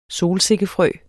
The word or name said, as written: solsikkefrø